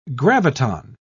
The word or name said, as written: graviton